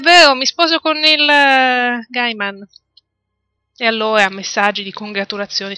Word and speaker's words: Gaiman: Comunque, Veleneo mi dice che si pronuncia Gaiman. Io ho sempre detto Gaiman.